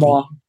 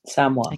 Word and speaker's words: Samoa: I mean, when you do a Google image search of Samoa, actually, how what is the correct way to pronounce Samoa? – Firstly? Samoa. – Samoa. – Yeah, Samoa. Okay.